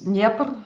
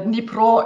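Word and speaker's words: Dnipro (Дніпро): Und deswegen wurde es dann ins Deutsche als Kiew integriert, aber das ist tatsächlich die russische Aussprache, genauso wie der Fluss Dnieper, ne, also auf Russisch, auf Ukrainisch der Dnipro ist.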